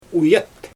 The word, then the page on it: ujat: Xyzyl